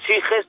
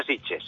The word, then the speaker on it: Sitges: Sí, se escribe Sitges, pero se pronuncia Sitges.